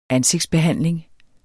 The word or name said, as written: ansigtsbehandling